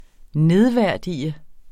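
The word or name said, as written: nedværdigede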